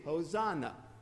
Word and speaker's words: Hosanna: But in English, if we have an S in front of an accent and syllable, we change it into a little Z. So you probably say it with a little Z, Hosanna.